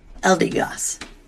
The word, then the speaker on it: Eldegoss: That is a Gardevoir, a Venusaur, Incineroar, a Dreadnought, and Eldegoss.